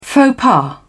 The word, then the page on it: faux pas: British English